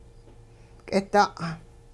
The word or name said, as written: k'ida'